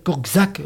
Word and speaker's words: Korczak: Hein, Korczak, c'est prononcé comme Akobe, il est en polonais. – Oui, Korczak.